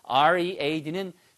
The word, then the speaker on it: read: 그래서 READ는 스펠링은 철자는 똑같고요. read라고 하시면 읽다라는 뜻이에요. I read your mind. 그러면 항상 마음을 읽는 거죠. 이게 아니고 과거분사 전부 다 읽었어, 읽었었어는 I read. 발음이 read로 바뀝니다.